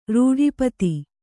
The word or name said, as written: ರೂಢಿಪತಿ